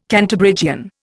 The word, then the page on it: Cantabrigian: Indian